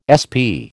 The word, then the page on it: SP: American English